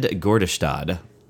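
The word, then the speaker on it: Gärdestad: The other person whose name is involved is a guy named Ted Gordestad, which we're going to go with that pronunciation, I guess. So yeah. That's probably right.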